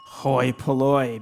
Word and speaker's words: hoi polloi: Oh, gee, the best I can do is it both words rhyme with boy? – Hoi polloi, baby.